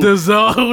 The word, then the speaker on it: DAZN: D-A-Z-N. – Go ahead. – How do you pronounce what is it? – That? – D-A what? – It's on the Z-N. Paper. D-A-Z-N. That's the name of the streaming service. – I'm going with D-A-Z-N.